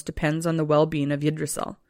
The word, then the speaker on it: Yggdrasil: So the cosmos, speaking of, were supported by a huge ash tree called Yggdrasil. – Yggdrasil. – You probably know how to say it. – Idris Elba. – No. – Idris Elba? – Not Idris Elba. No. Yggdrasil.